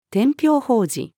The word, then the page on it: 天平宝字: female